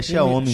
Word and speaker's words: Xiaomi: Xiaomi. É, Xiaomi.